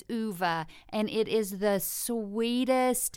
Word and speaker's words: Ove: And my favorite book this year, is A Man Called Uwe. It's spelled O-V-E. And so I always thought it was A Man Called Ove until I literally could not put the book down.